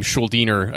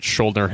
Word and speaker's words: Schuldiner: His mother was from the American South, and his father was of Jewish Austrian ancestry, hence his last name, Schuldiner, a German language, and pronounced as such, although oftentimes he was known for pronouncing it Schuldner himself and didn't often correct people in interviews.